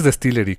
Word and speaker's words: DSTLRY: Sí, es que me niego a pronunciarlo mal, es Distillery, como destilaría y se friegan, ¿no?